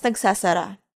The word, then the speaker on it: nagsasara: Followed by nagsasara, which means close. Let's break it down by syllable and hear it one more time. Nag, sa, sa, ra, and nagsasara.